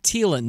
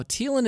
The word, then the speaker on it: Thielen: This one comes from Zach Thielen. Now, Thielen is spelled T H I E L E N, and some people might say Thielen, and maybe I should have said Thielen,